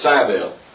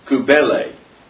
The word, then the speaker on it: Cybele: We probably call it Cybele. But it comes from the Greek word meaning, or pronounced kubele. And that's, I think, how we ought to put the emphasis on the syllables.